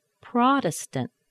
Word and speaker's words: Protestant: Protestant. Protestant. And we've got three T's here. Protestant. Okay, first T is the beginning of an unstressed syllable. It's pronounced like a D.